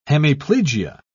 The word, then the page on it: hemiplegia: British English